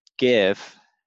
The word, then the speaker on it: GIF: I actually came across an email from Aaron that was specifically regarding standing out with video, and I shot him over a GIF. Is the correct pronunciation.